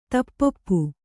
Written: ತಪ್ಪೊಪ್ಪು

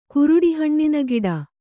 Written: ಕುರುಡಿಹಣ್ಣಿನಗಿಡ

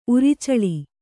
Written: ಉರಚಲ್ಲಿ

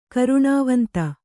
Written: ಕರುಣಾವಂತ

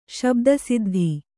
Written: ಶಬ್ದಸಿದ್ಧಿ

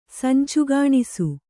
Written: ಸಂಚುಗಾಣಿಸು

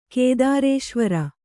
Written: ಕೇದಾರೇಶ್ವರ

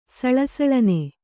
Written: ಸಳಸಳನೆ

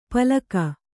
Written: ಪಲಕ

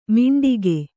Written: ಮಿಂಟಿಗೆ

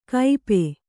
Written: ಕೈಪೆ

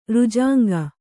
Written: ರುಜಾಂಗ

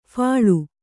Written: ಫಲಿ

